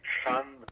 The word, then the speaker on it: Phan: Et leur nom de famille est Fan Morissette, P-H-A-N, et Morissette, un bon vieux nom québécois, si on peut dire.